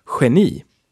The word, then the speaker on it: génie: We also have a lot of French loan words, so G can also be pronounced like H. So we have the word for genius, which is génie. Génie.